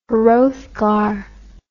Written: Hrothgar

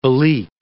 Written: Belleek

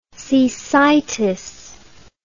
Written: cecitis

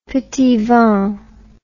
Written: petit vin

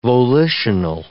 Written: volitional

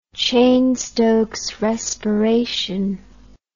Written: Cheyne-Stokes respiration

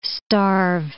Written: starve

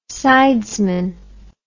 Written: sidesman

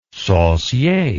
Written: saucier

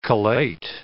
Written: collate

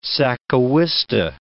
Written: sacahuiste

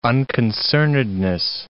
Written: unconcernedness

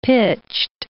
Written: pitched

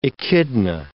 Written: echidna

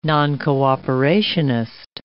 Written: noncooperationist

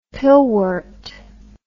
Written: pillwort

